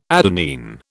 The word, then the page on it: adenine: British English